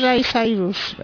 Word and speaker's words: Miley Cyrus: Yo no sé cómo se dice, Miley Cyrus. – Miley Cyrus.